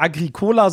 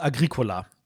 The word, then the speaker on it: Agricola: Aber tatsächlich die korrekte Aussprache ist natürlich nicht Agricola, sondern Agricola.